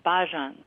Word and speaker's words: bhajans: Bhajans. – Okay, so Bajans. What were you singing before? – It's not French. Bhajans.